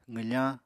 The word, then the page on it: qwfañ: apatani